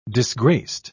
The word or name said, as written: disgraced